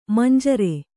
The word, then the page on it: ಮಂಜೂರಿ: manjūri